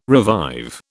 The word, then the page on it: revive: British English